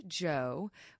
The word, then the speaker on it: Djo: So the artist Joe, which is spelled D-J-O, it is Joe Keery, who is also an actor, one of the cast members for Stranger Things.